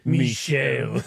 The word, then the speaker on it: Mishael: It was Hanani, Mishael, and Azira. – Mishael. – It's with an S. I'm pretty sure that's how it's said. – Hey, I'm just white. W-H-I-T. – So Meshach was e. Mishael. M-I-S-H-A-E-L.